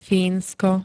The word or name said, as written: Fínsko